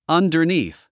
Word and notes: underneath: ʌ`ndə r níːθ; アンダァニース

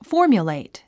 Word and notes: formulate: fɔ́ː r mjulèit; フォーミュレイト

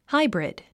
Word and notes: hybrid: hǽibrid; ハイブリド

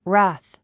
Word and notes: wrath: rǽθ; ラァス

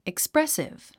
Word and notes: expressive: eksprésiv; エクスプレスィブ

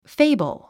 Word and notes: fable: féibl; フェイブル

